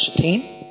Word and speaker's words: Shittim: Early in the morning, Joshua and all the Israelites set out from, now this is pronounced Shittim, and went to the Jordan.